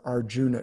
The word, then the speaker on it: Arjun: There's another episode concerning Krishna and a hero whose name is Arjun or Arjuna. It's spelled Arjuna, but if you're cool in Hindi, I think you almost always drop off that last A. So they say Arjun.